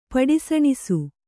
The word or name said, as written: ಪಡಿಸಣಿಸು